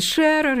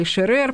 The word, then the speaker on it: Шерер: с панорамы, так сказать, диалогов в салоне Анны Павловны Шерер или Шерер, по-разному ставят ударения, которые все сплошь на французском языке.